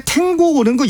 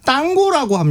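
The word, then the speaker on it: tango: 근데 탱고는 그 영어식 발음이고 현지에서는 그 땅고라고 합니다. – 땅고. 아, 스페인식으로 읽으면 그렇게 읽는 게 맞죠.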